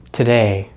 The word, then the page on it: today: American English